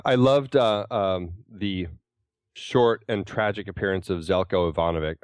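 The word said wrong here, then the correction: Željko Ivanek: or Ivanek. I can't really ever say his name. – Oh, he was Zeljko Ivanek. – Zeljko Ivanek.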